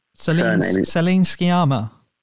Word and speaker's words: Sciamma: I had to pronounce her Céline Sciamma.